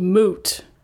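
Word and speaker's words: Mout: It's M-O-U-T, and apparently I mispronounce it. It's called Old Moot.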